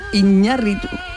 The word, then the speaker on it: Iñárritu: Bueno, algo que hemos estado comentando en la primera hora con Fernando Iwasaki, que es la presencia de Alejandro González Iñati, Iñarritu. Vaya. Iñarritu eh, lo he dicho bien. – Eh, lo he dicho bien, ahora sí, sí.